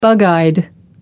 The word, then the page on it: bug eyed: American English